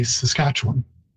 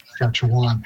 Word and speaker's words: Saskatchewan: And but it is interesting that I already mentioned earlier that people inside the province say Saskatchewan, and outside you hear that Saskatchewan. It's actually because we know how to pronounce it. We've heard it all along since it was borrowed and have kept closer to the Cree pronunciation than outside, where people see the spelling W-A-N and so mispronounce it as Wan.